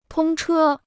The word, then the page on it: 通车: tōng chē